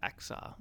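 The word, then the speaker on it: Achsah: But and even his wife, Aksa, and that's how you say it, A-C-H-S-A.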